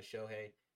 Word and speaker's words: Shohei: Shout out to Shohei.